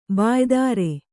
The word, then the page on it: ಬಾಯೊದರು: bāyodar